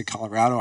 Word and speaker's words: Colorado: I've been killing Colorado too. Sorry, that's my East Coast side. I need to say Colorado.